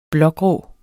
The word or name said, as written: blågrå